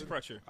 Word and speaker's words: Crutcher: Crutcher. – Yeah, Terrence Crutcher.